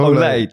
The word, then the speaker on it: Olij: Dat klemtoont al niet lekker. Want hij heet Nick Olij, toch? – Ja.